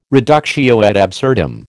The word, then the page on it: reductio ad absurdum: American English